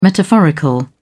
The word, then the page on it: metaphorical: British English